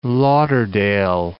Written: Fort Lauderdale

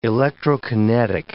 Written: electrokinetic

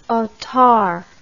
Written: otard

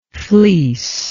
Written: fleecer

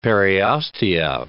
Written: periostea

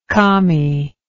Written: calmy

